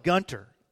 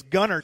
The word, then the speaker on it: Gunter: Don't call it Gunter. They will run you out of town if you pronounce the T. It's Gunner, Texas, even though it's spelled Gunter, Texas.